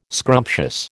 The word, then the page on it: scrumptious: American English